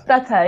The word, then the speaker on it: Pratha: Pratha, yes, you're saying Pratha.